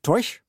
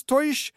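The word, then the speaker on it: Taoiseach: Och titeln för den irländska regeringschefen är Tauiseash, men uttalas säkert Toish. Mm. Och betyder ungefär hövding. Jaha. – Det hör man sig inte så ofta på nyheterna. Irlands Toish Leo Varadkar har i dagarna besökt.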